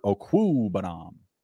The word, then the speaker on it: Okwuegbunam: Uh, Gerald Everett or Albert Okwuegbunam? – Man, that was that was a nice pronunciation there. I like I like you went you went for full. You didn't say Albert O. I love it.